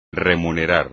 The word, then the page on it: remunerar: espagnol